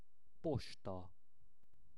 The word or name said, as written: posta